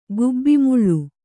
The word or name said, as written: ಗುಬ್ಬಿಮುಳ್ಳು